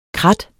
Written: KRATTETS

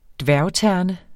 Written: dværgterne

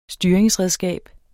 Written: styringsredskab

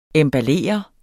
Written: EMBALLER

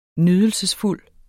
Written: nydelsesfuld

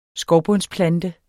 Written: skovbundsplanter

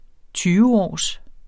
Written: tyveårs

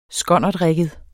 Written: skonnertrigget

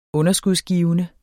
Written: underskudsgivende

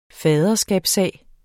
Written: faderskabssag

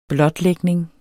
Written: blotlægning

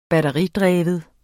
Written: batteridreven

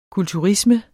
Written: kulturisme

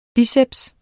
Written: biceps

